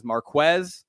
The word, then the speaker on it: Marquez: Remember that, anyone trying to pronounce his name correctly, it is Marquez. He made that very clear. We got a lot going on.